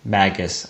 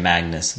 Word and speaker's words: Magus: Magus. I think we've been saying Magnus, and that's not right.